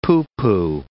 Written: pooh-pooh

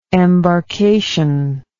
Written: embarcation

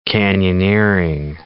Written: canyoneering

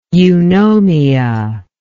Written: eunomia

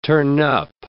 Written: turnup

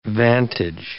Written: vantage

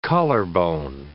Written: collarbone

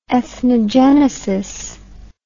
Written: ethnogenesis

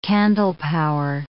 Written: candlepower

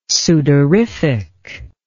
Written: sudorific

